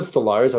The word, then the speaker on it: Stolarz: Okay, actually, as soon as you said Stolarz, I was like, oh my God, that is totally how you say it.